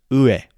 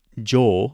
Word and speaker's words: 上: So if you see it behind something else, we're going to see it as ue, okay? But if it's jo, long u sound, following a noun, so we see above, symbol above a noun.